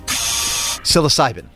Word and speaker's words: psilocybin: I said this wrong every time. Psilocybin. – Psilocybin. – Yep.